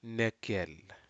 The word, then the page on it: nekel: Aneityum